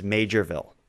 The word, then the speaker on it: Maugerville: Well, I'll tell you, based on my emails, I certainly said Maugerville. It's spelled like Maugerville. Mm-hmm. I assumed it was Maugerville. The actual place name is Majorville. As many listeners connected to New Brunswick were kind enough to inform me of my error. – That was a major mistake you made. – Well, the ridiculous town spells it Mauger. Who would pronounce that major? I just assume Mauger, but anyway, it's Majorville.